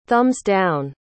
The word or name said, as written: thumbs-down